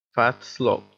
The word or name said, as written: fat slob